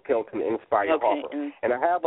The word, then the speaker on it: Nspire: I can be found on Facebook at Kelton Inspire Harper. And I do want to just let everyone know that Inspire is spelled with the letter N, N-S-P-I-R-E, and not I-N, just the letter N, Kelton Inspire Harper.